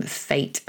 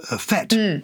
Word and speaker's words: fête: Tapio is one of our North American listeners and tells us that in the US it's pronounced fete. – Oh, there you go. – But in English we say fate.